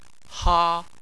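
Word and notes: ha1: tone 1

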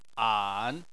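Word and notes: an: tone 3

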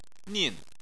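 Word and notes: nin: tone 4

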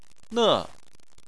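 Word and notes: ne: tone 4

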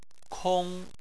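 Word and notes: kong: tone 1

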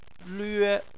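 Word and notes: lve: tone 1

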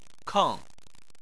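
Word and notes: keng: tone 4

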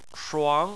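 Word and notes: shuang: tone 2

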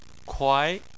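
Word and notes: kuai: tone 2

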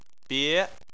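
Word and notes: bie: tone 2

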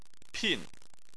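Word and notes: pin: tone 4

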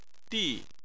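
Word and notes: di: tone 4

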